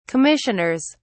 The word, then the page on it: commissioners: British English